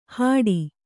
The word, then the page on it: ಹಾಡಿ: hāḍi